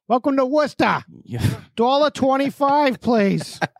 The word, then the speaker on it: Worcester: So you pronounced it correctly. – Did yeah. – I? – Yeah, a lot of people say Worcester.